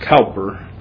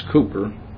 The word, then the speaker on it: Cowper: The name looks like it should be pronounced Cowper, but it's Cooper.